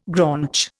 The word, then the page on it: graunch: Indian